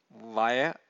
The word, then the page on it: vaya: Pali